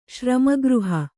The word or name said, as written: ಶ್ರಮಗೃಹ